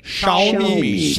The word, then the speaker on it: Xiaomi: Xiaomi.